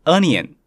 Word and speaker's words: onion: 洋葱是 onion，虽然开头是 o，但不念作 o 之类的音哦，而是念 a，跟 love，I love you 的 love。二是一样的，onion。